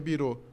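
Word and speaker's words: bureau: B-I-R-O chapeuzinho. Não virou Bureaux, não, porque a original é Bureaux, né? Aí se pronuncia birô, mas aqui não.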